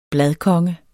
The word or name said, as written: BLADKONGE